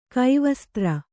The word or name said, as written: ಕೈವಸ್ತ್ರ